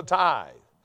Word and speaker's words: tithe: I was reading it and pronouncing it tithy. I didn't know what a tithy was. I had to look it up in a dictionary and found out it wasn't a tithy, it was a tithe.